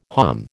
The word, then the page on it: halm: American English